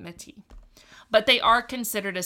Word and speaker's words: Métis: And then I'm going to mention, because this comes up later in the story, the Métis. I don't think the S is pronounced. So it's M with an E and a little accent mark, T-I-S.